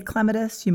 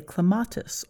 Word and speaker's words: clematis: I say cleome, you might say cleome. I say clematis, you might say clematis or clematis. It's all accepted, so you never have to worry about that.